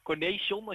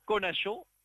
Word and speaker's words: Cornecion: Die heet C-O-R-N-E-C-I-O-N. Cornation, als je dat een beetje op die manier kan vertalen. Maar ja. Het was een beetje met een Franse uitspraak. Zo, dat is de juiste uitspraak.